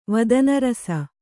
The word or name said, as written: ವದನರಸ